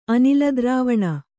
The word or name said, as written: ಅನಿಲದ್ರಾವಣ